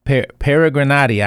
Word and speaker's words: Peregrinati: I'm going to try to pronounce it Peregrinati. I may or may not have gotten it correctly, but we're grateful to have them. And they're going to tell us about the work they do. They're musicians, amongst other things. Steve and Shirley, how are you all today? – Oh, we're great. You did great on the pronunciation.